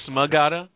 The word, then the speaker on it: Smagata: The silent S in Magata? – Yeah. Magata. No. Oh, it's Smagata?